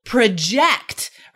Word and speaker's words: project: Emphasis on the second syllable, project, right?